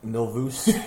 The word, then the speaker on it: Milvus: They're um, how would you pronounce that properly? – Milvus. Milvus.